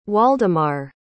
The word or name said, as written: Waldemar